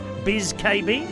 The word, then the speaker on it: Bzikebi: Anyway, that's the song we're going to hear this morning by a singer called Biz KB. B-Z-I-K-E-B-I. Biz KB.